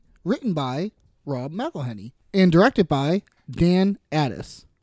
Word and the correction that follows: Attias: I might be pronouncing his name wrong. I got Attias? – Yeah. Is it Attias? – I think it's Attias.